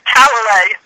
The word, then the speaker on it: Talalay: I think I butchered your last name, but am I pronouncing it right? – You just butchered it. Talalay.